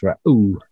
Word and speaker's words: Reu: And so, for example, in verse 18 to 21, we've got this R-E-U character, which in Hebrew is roughly pronounced Ra'u.